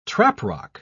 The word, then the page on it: traprock: British English